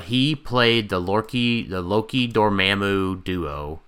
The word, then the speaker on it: Dormammu: Dormammu. – No, it's Dormammu. – Oh, I apologize. – No, sorry. No, I said it wrong, didn't I, Jason? It's you did. Dormammu. – Dormammu. Yeah, it's Dormammu.